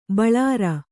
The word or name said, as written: ಬೋಳರೆ